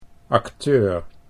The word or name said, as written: acteur